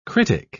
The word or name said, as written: critic